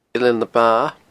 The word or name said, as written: Eilean na Ba